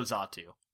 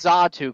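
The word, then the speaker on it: Xatu: Also Zatu. Again, almost made the list. – Pronounce Zatu correctly because it has a friggin' X on the front, but you can't pronounce Alolamola correctly.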